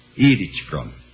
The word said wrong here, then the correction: Erich Fromm: Falei mal, né? Erich Fromm.